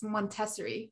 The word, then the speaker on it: Montessori: And then I went to, is it, by the way, it's Montessori. Isn't that how you say it? I don't know. – Yes. – Went to Montessori school for a year.